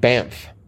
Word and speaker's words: Banff: Banff. – Damn, you do know that. – I knew that one right off the bat. – Uh-huh, and you know how to pronounce it.